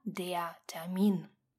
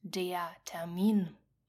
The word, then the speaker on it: Termin: Если вы с кем-то договорились о встрече или записались где-то на определенную дату и время, то это диатамин. Прошу вас обратить особое внимание на ударение, которое падает на второй слог. Повторим несколько раз, чтобы запомнить. Диатамин. Диатамин.